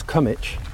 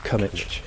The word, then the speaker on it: Combwich: Is that Cannington? – No, that's Cummitch. – Cummitch, okay. – Spelt Combwich, but pronounced Cummitch.